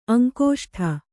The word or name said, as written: ಅಂಗುಷ್ಠ